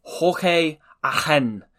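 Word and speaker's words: Jorge Allen: As the commentator once referred to him as, just to point out that that's his Argentine pronunciation of his name, but he was just going to call him George Allen for the rest of the game because he is of English parentage but was born in Argentina.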